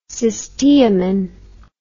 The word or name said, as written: cysteamine